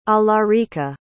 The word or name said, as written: alarica